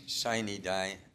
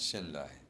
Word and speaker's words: sine die: So the correct pronunciation is sine die, not sendai.